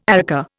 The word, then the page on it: areca: Indian